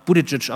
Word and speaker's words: Buttigieg: Und dann sagte er, ja, Buttigieg, aber äh, Sie können mich auch einfach äh, Bürgermeister Pete nennen.